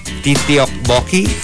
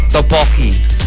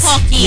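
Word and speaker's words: tteokbokki: And lastly, Helmerita says, I think they pronounce Titiokboki. – Yun na nga sinabi na nga, Tokboki. – Tokboki. They pronounce as Topoki. – Ah, Topoki, yes. – Topoki. – Yes, Topoki. Yeah, yeah, yeah, yeah. – So it's not correct. It's not Titiokboki.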